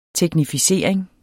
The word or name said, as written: teknificering